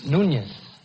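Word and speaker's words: Núñez: I pronounced your name correctly? – No, you didn't. Actually, it's Nunez. – Nunez? – It's Spanish.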